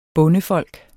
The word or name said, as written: bondefolk